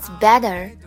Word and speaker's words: better: Always better, better, better 的 t, t 要把它浊化成 d.